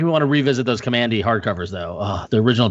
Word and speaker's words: Kamandi: While we were recording, I did tweet it out, and the overwhelming majority says that it's Kamandi. – Kamandi.